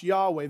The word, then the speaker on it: Yahweh: And that's why the Hebrews would speak of God as in four consonants, YHWH, that we would pronounce Yahweh.